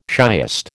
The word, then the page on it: shiest: American English